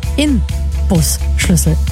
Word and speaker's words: Inbusschlüssel: Ha, dabei heißt es korrekt Inbusschlüssel. Verrückt. Mit N, wie Nordpol.